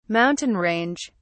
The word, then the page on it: mountain range: British English